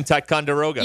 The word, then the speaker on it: Ticonderoga: The, I can't ever pronounce the name. Tyra. – Dixon Ticonderoga. – Yes.